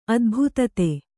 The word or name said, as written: ಅದ್ಭುತತೆ